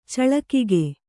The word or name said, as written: ಚಳಿಕಿಗೆ